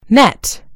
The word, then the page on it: net: American English